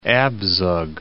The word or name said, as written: Abzug